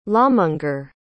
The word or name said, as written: lawmonger